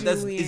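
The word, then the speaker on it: Julien: Like, Julian.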